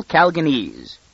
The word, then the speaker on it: Calganese: "Now, why do these terrible Calganese," she accented the second syllable and gave it a long A, "do like that?